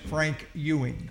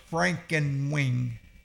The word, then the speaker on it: Frankewing: It was spelled Frankenwing, but we found out it's pronounced Frank Ewing. – Okay. – F-R-A-N-K. E-W-I-N-G. So I thought it was Frankenwing, but it's pronounced Frank Ewing.